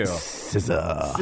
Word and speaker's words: SZA: SZA. – SZA, spelled like SZA. S-Z-A.